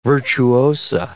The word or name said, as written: virtuosa